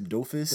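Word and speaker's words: Dofus: It's D-O-F-U-S. Doofus.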